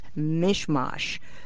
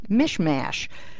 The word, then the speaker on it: mishmash: A really famous mispronunciation is mishmash, which many politicians trying to sound as if they speak Yiddish pronounce mishmash. And Groucho Marx once said to somebody, "If you pronounce it like that, you're never going to get votes in the Bronx."